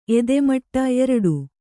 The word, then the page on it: ಎದೆಮಟ್ಟ: edemaṭṭa; Kannada